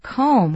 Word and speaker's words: comb: In the spelling K-E-M-P-T, you can maybe see the word comb, which we spell C-O-M-B, and we don't pronounce the B.